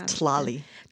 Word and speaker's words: Tlali: She is one of the owners of, and I might be pronouncing the restaurant name incorrectly, but Thalia, over on A Street here in Ashland, a delicious Mexican restaurant. – I think it's Tlali.